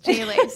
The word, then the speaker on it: Gigli: In our defense, it's spelled G I G L I. Apparently pronounced Jiggly, but looks like Jiggly. Yeah, anyway, it's Jiggly.